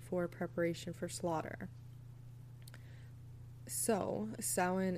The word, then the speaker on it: Samhain: It's literally spelled like Samhain, Samhain. But its etymology and the origin of the word is it's pronounced Samhain.